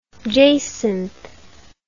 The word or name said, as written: jacinthe